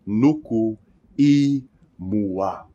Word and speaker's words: imua: When we see an I, as we do here, remember it is pronounced E, like in the word easy. So, nuku imua.